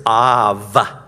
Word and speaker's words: Av: And the Hebrew word for father is Av, with a V, Av, Avraham, father of the nations.